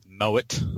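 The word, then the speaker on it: Moët: And nothing says civilised like ordering a glass of Moet. – Hey, smartass, that's actually how the French pronounce it.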